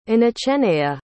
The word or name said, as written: inoceneia